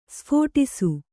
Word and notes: ಸ್ಫೋಟಿಸು: sphōṭisu

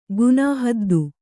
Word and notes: ಗುನಾಹದ್ದು: kannada

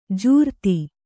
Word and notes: ಜೋರತ್ತು: Kannada

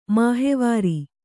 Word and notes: ಮಹಾವೀರ: mahāvīra

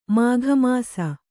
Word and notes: ಮಾಘಮಾಸ: Kannada

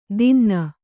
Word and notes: ದಿನ್ನ: dinna

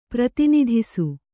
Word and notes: ಪ್ರತಿನಿಧಿಸು: prati nidhisu

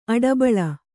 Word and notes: ಆಡುಬಳ್ಳಿ: āḍubaḷḷi

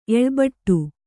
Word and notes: ಎಳ್ಬಟ್ಟು: Kannada

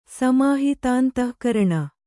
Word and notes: ಸಮಾಹಿತಾಂತಃಕರಣ: Kannada

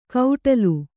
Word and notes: ಕೌಟಲು: kannada

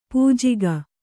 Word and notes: ಪೂಜೆಗೈ: Kannada

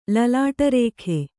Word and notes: ಲಲಾಟರೇಖೆ: lalāṭa rēkhe

